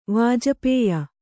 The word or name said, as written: ವಾಜಪೇಯ